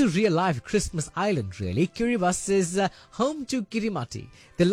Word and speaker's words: Kiribati: Now, many people get this wrong, right? It's spelled Kiribati, but actually pronounced Kiribati.